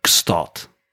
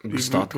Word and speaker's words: Gstaad: maar joh, ik moet het even goed uitspreken, want het is een vrij specifieke uitspraak. Maar Gstaad. Gstaad. Ken je die plek in Zwitserland? Gstaad. – Nee, ik weet niet van Gstaad. – G en dan S-T-A-A-D. Gstaad.